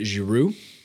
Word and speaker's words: Giroud: You pronounce it Giroud? – Yeah.